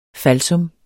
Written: falsum

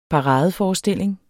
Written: paradeforestilling

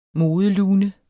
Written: modelune

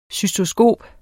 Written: cystoskop

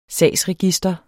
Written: sagsregister

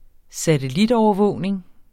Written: satellitovervågning

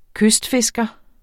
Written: kystfisker

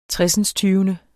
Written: tresindstyvende

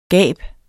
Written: gab

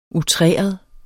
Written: outreret

